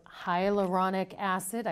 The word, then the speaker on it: hyaluronic acid: We're hearing a lot about hyaluronic acid. I hope I'm saying that right. – Yeah, you said it perfectly.